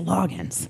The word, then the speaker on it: Loggains: His last name is spelled Loggains, but it's pronounced Loggins. It drives me crazy.